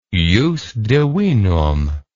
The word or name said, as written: jus divinum